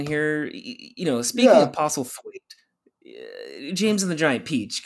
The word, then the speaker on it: Postlethwaite: Postlethwaite.